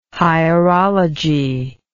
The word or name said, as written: hierology